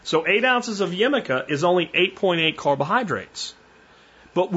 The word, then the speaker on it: jicama: Uh, we're going to use a magical vegetable called jicama, and it's spelled like jicama, J-I-C-A-M-A, jicama, and this is a root vegetable native to Central and South America, grows as a tuber, and it tastes mostly like apple.